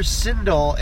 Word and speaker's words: Sindel: There's so many good names and you call her Sindel. It's like, it rhymes with Bindle.